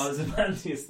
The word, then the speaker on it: Ozymandias: Ozymandias, thank you.